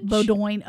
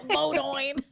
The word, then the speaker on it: Bowdoin: Bowdoin. Oh, yeah. – Bowdoin.